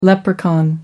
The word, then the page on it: leprechaun: American English